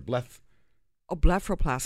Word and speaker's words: blepharoplasty: Blepharoplasty.